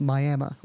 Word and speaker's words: Miami: So there's a town in Oklahoma that's spelled Prague, like the country, but it's pronounced Prague in the same way that Miami, Oklahoma is pronounced Miami, like officially.